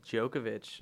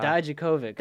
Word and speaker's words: Dijakovic: And Dominik, how do you say this one? You had it mastered before the show. – No, I've been calling him Djokovic. – Dijakovic. – Dijakovic. Oh, yeah.